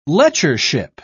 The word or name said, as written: lectureship